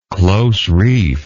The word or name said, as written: close-reefed